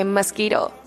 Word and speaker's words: mosquito: Okay, mosquito. Pay attention to the UI sound. We pronounce it like E. Okay, mosquito. And a Q, the letter Q pronounced like G here. Mosquito, not mosquito.